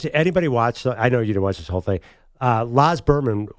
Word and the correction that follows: Luhrmann: Baz Luhrmann.